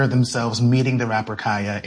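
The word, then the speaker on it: Khia: I am going to, in this conversation, pronounce Kaya, the rapper, with the long I. Kaya, okay? My neck, my back, for those who need a refresher. Kaya, okay? Mm-hmm. The Kia Asylum is a mispronunciation of her name.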